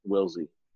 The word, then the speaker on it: Willsey: My Twitter is my name, Teddy Willsey, T-E-D-D-Y, and then W-I-L-L-S-E-Y.